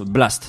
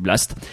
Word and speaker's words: BLXST: songwriter et producteur de Los Angeles du nom de BLXST, qu'on prononce Blast, ça sera plus simple. – Oui. – Voilà, on dit Blast.